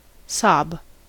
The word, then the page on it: sob: American English